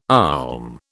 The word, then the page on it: olm: British English